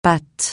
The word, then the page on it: pat: francais